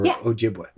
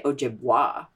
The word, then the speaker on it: Ojibwe: Yeah, some people say Ojibwa. – Or Ojibwa. That's like the Quebecois version of it. Yeah, I guess so. I say Ojibwe and I spell it W-A-Y. Some people spell it W-E, but I grew up spelling it W-A-Y.